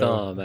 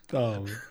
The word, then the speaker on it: Thom: So, Thom, actually, is pronounced. – Thom, okay. – T-H-O-M.